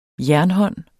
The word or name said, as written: jernhånd